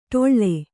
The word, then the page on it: ಡೊಳ್ಳ: Kannada